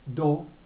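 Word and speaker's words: do (道): En japonés la palabra se pronuncia do, como sendo, que es el camino, pero es el lugar de la práctica, es la manera de practicar.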